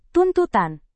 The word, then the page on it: tuntutan: indonesian